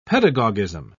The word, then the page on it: pedagogism: British English